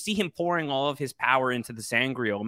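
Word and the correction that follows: sa'angreal: So Angreal. Yeah. And then there's Sa Angreal.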